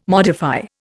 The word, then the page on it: modify: Indian